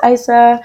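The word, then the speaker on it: Isa: I'm like, no, it's Aissa.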